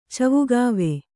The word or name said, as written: ಚವುಗಾವೆ